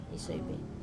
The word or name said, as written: soive